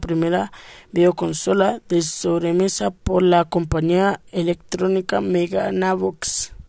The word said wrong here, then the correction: Magnavox: La Magnavox, lo dije mal, Odyssey fue creada por Ralph Baer, considerado como el padre de los videojuegos.